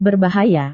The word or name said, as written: berbahaya